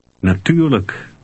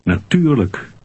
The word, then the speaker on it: natuurlijk: Natuurlijk. – Did you remember to pronounce the unstressed L-I-J-K ending? – Look. – Read 5 again. – Natuurlijk.